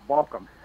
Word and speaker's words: Baucom: Now, do you pronounce your last name Baucom or Baucom? – Well, Baucom. – It's Baucom? – You're almost there. Yes. – It's pronounced Baucom.